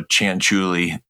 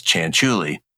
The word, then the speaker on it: Chanchuli: Um, so they announced my name and I spelled it phonetically. So Chanchuli, they would know. I wrote C H A N C H E W L E E. So they would know it's Chanchuli.